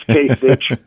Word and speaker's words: Kasich: This is for Ed, so he can pass it on to his buddy Bill Bennett, who doesn't know how to pronounce the name right. – Kasich? – Yeah, it's Kasich.